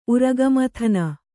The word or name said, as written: ಉರಗಮಥನ